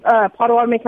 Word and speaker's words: Pottawatomie: We are in Pottawatomie County, so we're going to be the Pottawatomie County chapter of Oklahoma.